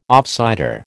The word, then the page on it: offsider: American English